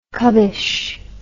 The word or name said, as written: cubbish